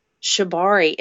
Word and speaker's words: Shibari: Shibari, am I saying that right? Shibari? – Yeah.